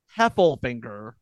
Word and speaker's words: Heffelfinger: I've been saying Heflinger all episode long. It is Heffelfinger Heffelfinger. Heffelfinger. Okay. So now I can say this properly.